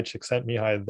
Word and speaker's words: Csikszentmihalyi: trying to get better at whatever you're doing so that's that's where some of the you know initial research and Mihaly Csikszentmihalyi, the the psychologist who originally coined the term flow.